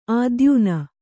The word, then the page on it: ಆದ್ಯೂನ: ādyūna